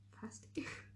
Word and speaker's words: pasty: Is it pasty or pasty? – Pasty? – Pasty. – P-A-S-T-I-E? – No, P-A-S-T-Y.